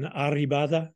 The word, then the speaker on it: Arribada: They said the Ridley sea turtles were landing on the beach in Arribada. Am I saying that correctly? Uh, yes.